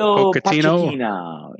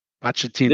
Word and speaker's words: Pochettino: Mauricio Pochettino. Yeah, Poch. – Pochettino.